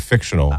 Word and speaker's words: fictional: But just, fictitional, you add a syllable instead of fictional.